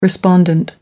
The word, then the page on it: respondent: American English